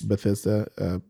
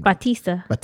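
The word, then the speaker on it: Bautista: Bethesda. But I can't say, I can never say his name. – Batista. – Because he be talking some shit about David Batista.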